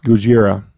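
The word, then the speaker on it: Gojira (ゴジラ): In Japanese, it's pronounced Gojira, which translates roughly to gorilla whale.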